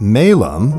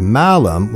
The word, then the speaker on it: malum: The apple conflation became popular during the Middle Ages because in Latin, the word malum with a long A means apple, and the word malum with a short A means evil. Malum and malum, and the similarity proved irresistible to Latin-speaking interpreters of the story.